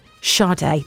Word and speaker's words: Sade: It's spelled S-A-D-E, Sade. But that's not how you say it, Sade.